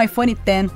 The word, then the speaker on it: iPhone X: O aguardado iPhone X, ou iPhone X, foi oficialmente anunciado, confirmando a pronúncia como iPhone X.